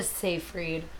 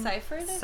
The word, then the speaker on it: Seyfried: Specifically, Amanda Seyfried. That's how you say her name. – Seyfried.